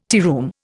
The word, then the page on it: tearoom: Indian